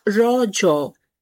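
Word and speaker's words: Raja: The festival is natively pronounced as Rojo, a local term that means menstruating woman.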